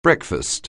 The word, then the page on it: breakfast: anglais